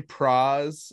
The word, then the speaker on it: Pras: Pras uh, rhymes with bras, right, Colin? That's right. That's right.